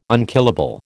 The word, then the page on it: unkillable: American English